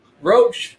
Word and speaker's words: Roche: Roche, just like the bug.